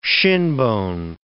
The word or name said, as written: shinbone